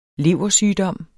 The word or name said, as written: leversygdom